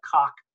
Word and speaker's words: Koch: We're going to see banks and corporations like Monsanto and everything touched by the Koch brothers. That's the correct pronunciation, by the way, the Koch brothers, okay?